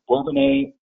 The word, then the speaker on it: Bourbonnais: Jenny Chaffer, she is the owner of Journeys Travel, and I'm going to mispronounce this, Jenny, Bourbonnais, Illinois. – Correct.